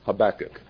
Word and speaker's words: Habakkuk: Some pronounce it Habakkuk. Others pronounce it Habakkuk, and Scorby pronounces it Habakkuk. Therefore, it is with the most rigorous insistence that we recommend Habakkuk.